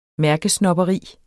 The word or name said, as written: mærkesnobberi